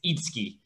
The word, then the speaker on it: Itsuki: So in that name, the U isn't pronounced. I don't expect anyone to know that, but it's... – Okay. – it's not you. It's a, you know, it's a Japanese name, but it's Itsuki.